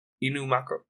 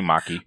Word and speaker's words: Inumaki: We get to see them in different lights, such as Panda, Maki, and Inumaki? Inumako? I forget how to pronounce his name. – Inumaki.